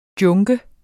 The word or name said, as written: junker